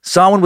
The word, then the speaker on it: Samhain: Between October 31st and November 1st, spelled Samhain, pronounced Samhain. So that's cool. Thanks for making it easy, Celts.